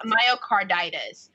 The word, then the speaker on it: Myocarditis: Myocarditis, I believe that's it. – Myocarditis. Myocarditis is the most well-known, I guess, form of this heart inflammation.